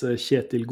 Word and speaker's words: Kjetil: Kjetil, can you help us out on the pronunciation here for the listeners too? Because like we were talking about before, everyone tends to say your name differently. It's got some nuance to it. And then we'll do our best for the rest of the episode. – Sure. Yeah. So my name is Kjetil Golid.